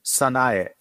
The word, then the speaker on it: Sanae: Sanae.